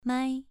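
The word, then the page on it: mai: tone 2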